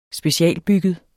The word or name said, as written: specialbygget